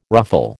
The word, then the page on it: ruffle: American English